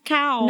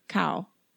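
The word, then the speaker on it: Cão: Yeah, it's C-A-O. It might just be pronounced Cao. – No, it's Cao. – Cao. – No, this was explained to me recently by somebody who speaks Portuguese. By the Portuguese explorer Diogo Cao,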